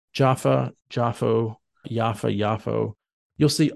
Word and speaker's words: Jaffa: And let me just say, too, sometimes you'll hear it pronounced Jaffa, sometimes Jaffa with a Y, sometimes Yafo with an O.